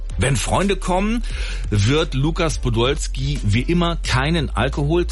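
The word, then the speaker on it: Łukasz: Vielleicht auch ein Grund, warum Lukas Podolski, wobei richtig ausgesprochen heißt er Lukas. – Lukas.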